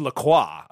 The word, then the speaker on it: LaCroix: It's like LaCroix. Like, that's pronounced LaCroix.